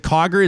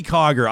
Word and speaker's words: Cogger: Is it Cogger? Is that how you say it? – Yes, Cogger. – Yeah. Zoe Cogger.